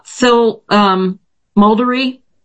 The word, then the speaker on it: Muldery: Phil Muldery. – I'm here. – Thank you. Did I get that right, Phil? – Yes. Accent on the first syllable.